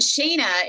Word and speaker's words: Shaena: Oh, and Shayna is spelled S-H-A-E-N-A. So Shay is S-H-A-E.